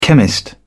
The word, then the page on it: chemist: British English